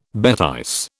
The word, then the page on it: bêtise: British English